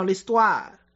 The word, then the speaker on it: l'histoire: 法语怎么讲？La restaurant，这拼写几乎完全一样，还有很多词比较很像的，比如说历史 history，发音叫 l'histoire，而法语当中的 oi 一定是发 wa 的音，l'histoire 是发 wa 的音，对吧？oi 很重要。